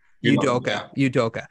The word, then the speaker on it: Udoka: And then they lost Udoka. Did I pronounce that right? – Yeah. – Udoka.